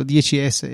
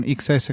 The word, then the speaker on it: XS: faccio ancora fatica comunque a pronunciarlo 10S e non XS come è scritto.